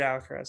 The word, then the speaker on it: Alcaraz: Lastly, my friend, I mentioned him earlier in the show, David Alcaraz, or David Alcaraz, sorry. I usually call him by is the Spanish pronunciation.